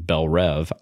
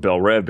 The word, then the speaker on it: Belle Reve: So I'm going with Belle Reve because there's only one E. There's no two E's.